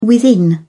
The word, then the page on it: within: British English